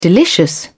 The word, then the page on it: delicious: British English